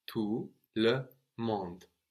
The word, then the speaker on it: tout le monde: We have tout, T-O-U-T, silent T on the end, related to total, tout le monde. All the world. Everybody. And again, with monde, we have the E on the end, showing us to pronounce that D, but this word isn't feminine, no, it's le monde.